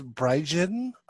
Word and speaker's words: Braijin: Um, Instagram is Braijin underscore CB. It's B R A I J I N. It rhymes with Gaijin.